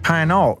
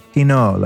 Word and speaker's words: Pineault: You know, his name is spelled P-I-N-E-A-U-L-T. When I got on the call with him, I said, what is it? It's Pinault, right? Right, man? It's Nick Pinault. He's like, no, it's Pinot, like the wine.